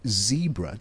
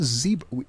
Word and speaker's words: zebra: I even have rangers who say zebra. Guys, it's zebra. We are not doing that here. It's a zebra. And so let internationals come to South Africa and say, oh, I learned to say it properly. Zebra is what it is.